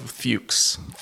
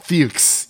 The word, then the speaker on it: Fuchs: F-U-C. – There's a couple ways you can go about pronouncing that one. – I know, and I'm hesitant right now. I'm just going to say... – I'll just go the safe route, Fuchs. – Fuchs, yes. Let's, for you know, we are a clean podcast here.